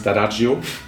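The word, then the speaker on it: D'Addario: Ob das jetzt Ernie Ball ist, ob das Dadario ist. Der Pro sagt übrigens Dadagio. – Dadagio? – Dadagio, genau. Können auch viele nicht aussprechen. Dann Dadagio. Italiener, ganz klar.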